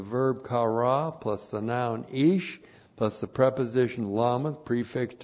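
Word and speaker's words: ish (איש): Aleph, long I, S-H. Now here is the word for man, ish.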